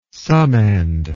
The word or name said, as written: summand